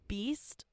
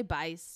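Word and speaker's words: Beiste: Most notably was Coach Shannon Beast. Is that how you pronounce it? Best? – I would say Beist.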